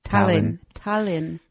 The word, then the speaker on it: Tallinn: Tallinn. – How do you say it? – Tallinn.